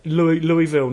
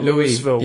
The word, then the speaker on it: Louisville: Louisville, not Louisville. – Yeah, Louis. – We just like Lewis, because remember, right. – Lewis from Epic Camp.